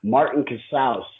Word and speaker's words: Martin Casaus: Martin Casaus, C-A-S-A-U-S.